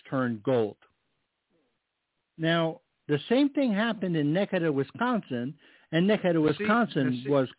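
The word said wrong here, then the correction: Necedah: Nisida is the way you pronounce it.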